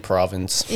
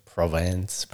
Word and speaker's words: Provence: Like, if it you're says, American, Provence. – Yeah, that's what it looks like. – If you're French, it's like. Provence.